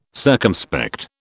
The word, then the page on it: circumspect: British English